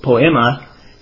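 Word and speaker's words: poiema: And that's the word, the Hebrew word, poemi. I'm mispronouncing that, poema, and all of those words is where we get our word poetry.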